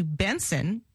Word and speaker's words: Bnxn: First, here's Finesse by Feels, featuring singer Buju, who has recently changed his name to Benson, and it's spelled B-N-X-N, by the way.